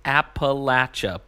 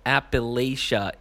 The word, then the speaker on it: Appalachia: He says, hey, it's pronounced Appalachia, buddy. I know you fellows are up in the great New England, but Appalachia is how folks say it when they don't know how to get any hillbilly on them.